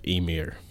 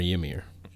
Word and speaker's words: Ymir: And that's with the story of Emir. Or Ymir, however you want to pronounce mm-hmm. – it. Not Weimer.